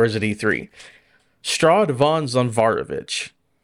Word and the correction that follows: Strahd von Zarovich: Are you pronouncing that correctly? Because that will change Strahd my answer. – Strahd Von Zarovich. – Strahd Von Zarovich. – Yes.